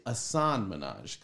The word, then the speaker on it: Hasan Minhaj: But he's actually Hasan Minhaj because you don't pronounce the H. Apparently, that's what white people do, he says.